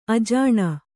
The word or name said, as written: ಅಜನಿ